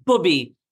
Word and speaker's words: Bubby: It's pronounced Bubby. – Bubby. – And nobody gets it right. So don't feel bad. Bub. It's a Yiddish, which is, you know, Jewish Yiddish word for grandma.